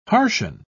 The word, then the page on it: harshen: British English